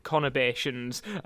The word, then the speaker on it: conurbations: I checked that I was pronouncing it right, and it's conurbations. – Yeah. – Sorry, conurbations.